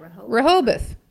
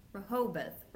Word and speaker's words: Rehoboth: How do you say that? – Rehoboth. – Rehoboth.